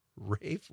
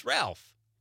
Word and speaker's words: Ralph: And then she said, I believe his name is pronounced Rafe, long A. I don't know. It's not Rafe, is it? It's Ralph. – And that looks like a Ralph. – It's Ralph Fiennes.